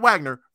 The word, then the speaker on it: Wagner: Uh, Wagner, not Wagner, Franz Wagner,